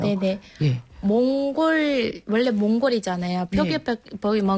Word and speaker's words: 몽골: L 뭐 L이 들어가요. 몽골이야. 그런데 예. – 이거를 이게 40 때 이후 한국 분들이 몽고라고 발음해요. – 몽고. – 몽골이 네. 아닌 몽고. – 네. – 몽고, 몽고, 몽고예요.